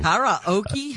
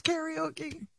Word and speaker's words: karaoke: Karaoke is not, we don't say it correctly. – Karaoke. Yeah. – Teach me how to say it. Karaoke. – Karaoke. – So when you hear someone saying karaoke, you get upset. – It's very, very incorrect. Yes, it is a bastardization of the Japanese word.